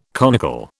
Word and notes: conical: British English

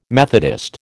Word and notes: Methodist: American English

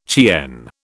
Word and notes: Cheyenne: British English

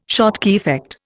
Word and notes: Schottky effect: Indian

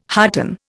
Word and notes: hearten: Indian

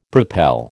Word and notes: propel: American English

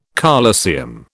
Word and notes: colosseum: British English

